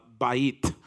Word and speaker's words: beth: And the Hebrew word that is translated house is beth or bet, and sometimes pronounced bait.